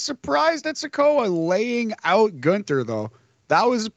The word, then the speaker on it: Gunther: Bivens called Gunther Gunna. Gunther said it's pronounced Gunther.